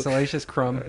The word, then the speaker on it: Salacious Crumb: Yeah, so, Salacious Crumb.